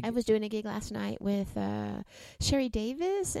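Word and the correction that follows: Sherry Davey: and I hope I'm saying that right, Davies. And Sherry Davy. – Sherry Davy.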